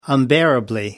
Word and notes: unbearably: British English

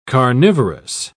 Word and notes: carnivorous: British English

